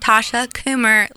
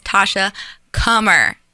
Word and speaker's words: Kummer: Yeah, it's Tasha Kummer. It's not Tasha Coomer like tumor is Tasha Kummer.